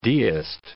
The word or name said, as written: deist